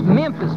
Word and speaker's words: Memphis: That's pronounced Memphis, boy. Memphis.